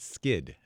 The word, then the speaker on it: Skydd: The one that seems to work the best for me is one called Plant Skid. That's spelled S-K-Y-D-D.